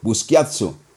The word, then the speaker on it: Buschiazzo: Fabrizio Busquiazzo, así como le gusta que lo pronuncie Chelo.